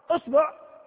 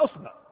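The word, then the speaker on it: إصبع: أصبع، هل تعلمون أنه ورد فيها 9 لغات؟ أصبع، نحن ننطقها أصبع، يجوز أصبع وأصبع وأصبع، ويجوز أيضا الباء تكون بضم الباء، أصبع وأصبع وأصبع، فنضرب 3 × 3 كم؟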